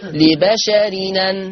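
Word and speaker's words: لبشرٍ أن: طبعا أنا كتبت التنوين مجازا حرف نون، لبشر أن تقرأ بالنقل، فلما انقل الهمزة المفتوحة يا إخوة يا أخوات للنون التنوين هتبقى نون التنوين منطقة نون إيه؟ مفتوحة، فيصير النطق لبشرين لبشرين. يبقى هكذا: ما كان لبشر أن تقرأ، ما كان لبشرين هكذا، يبقى لبشر أن هتبقى نون مفتوحة فتقرأ لبشر لبشرين.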